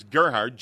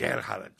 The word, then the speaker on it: Gerhard: But it's like got this guttural and you roll the R, and his first name is spelled Gerhard, but he pronounces it some other – we're going to have to ask him.